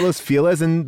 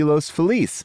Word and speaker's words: Los Feliz: It's the reason why people in Los Feliz call it Los Feliz, but if you were to correctly pronounce it, it would be Los Feliz.